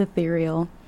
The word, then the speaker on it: ethereal: Yes, this is the word that I couldn't pronounce. It's ethereal.